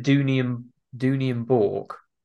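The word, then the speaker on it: Dooney & Bourke: they've said Dooney and Bork. That's how you say it.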